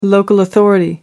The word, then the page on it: local authority: American English